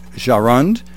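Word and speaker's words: Jahrund: It's spelled J-A-H-R-U-N-D. I'm going to say Jarund.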